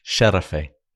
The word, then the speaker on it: Şerefe: Şerefe. – Good enough. Şerefe.